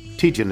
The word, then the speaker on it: Tietjen: He writes, please attach two songs from a new EP entitled Wax Wonder by Amy Julia Teachin. Tijin is how it's pronounced, which dropped on Spotify, Apple Music, and all major platforms on May 3rd.